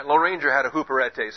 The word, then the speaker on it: huperetes: H-U-P-E-R-E-T-E-S. And, back up. Get my pen out. Huperetes would be the better pronunciation for that. Huperetes. These are your long E's here and here, and that's your short E that's got the accent above it.